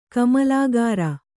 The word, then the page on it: ಕಮಲಾಕರ: kamalākara